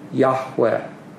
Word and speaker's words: Yahweh: What evidence we have strongly supports the theory that the ancient Israelites pronounced this word Yahweh.